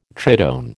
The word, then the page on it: tritone: American English